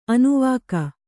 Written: ಅನುವಾಕ

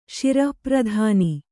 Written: ಶಿರಪ್ರಧಾನ

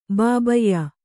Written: ಬಾಬಯ್ಯ

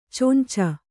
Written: ಚೊಂಚ

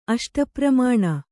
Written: ಅಷ್ಟಪ್ರಮಾಣ

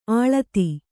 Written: ಅಳೆತ

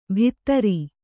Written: ಭಿತ್ತರಿ

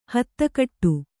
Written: ಹತ್ತಗಡೆ

